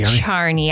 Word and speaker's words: Charney: Is it Charney or Charney? – Charney. I always wanted to pronounce it Charney, but oh, yeah. My husband said that's wrong. – It's very French.